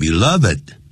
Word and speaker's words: beloved: 原来 he created the beloved comic strip，因为他创造了这个受人爱戴的 beloved 加 d 的时候会念成 beloved 啊，这个当形容词用哈。